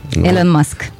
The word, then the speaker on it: Elon Musk: Elon Musk.